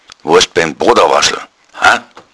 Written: bodawaschl

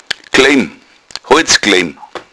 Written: klaim

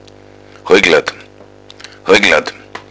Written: hoigladd

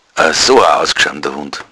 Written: ausgschamt

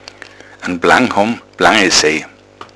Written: plangesej

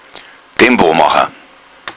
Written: tempo

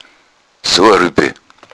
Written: ruepel

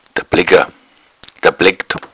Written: dablegga

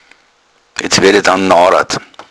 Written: narad

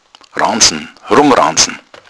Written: ranzn

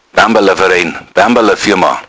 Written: bambala

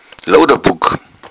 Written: luder

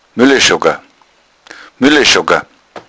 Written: muelleschogga

